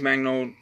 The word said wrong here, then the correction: Mangold: I think it's his pronounced Magnold, uh, Magnold, uh, whatever his name, how you pronounce it. Uh, I'll get to it in a minute. Um, James Mangold.